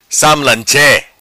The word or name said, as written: Sam Lan Che